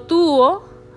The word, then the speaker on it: tuvo: So tuvo is spelled T U V O, but it may sound like tubo, okay, like tubo, or you know, it may sound like a B or a V.